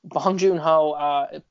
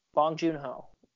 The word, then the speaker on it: Bong Joon-ho: And I think, I think Bong Joon-ho, is that how you pronounce his name? Did I say it right? – Yeah. – Bong Joon-ho, is that it? – Yes. – Bong Joon-ho. – Okay, it's correct, yes.